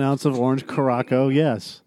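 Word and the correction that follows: Curaçao: Too early, it's like, it's Curacao.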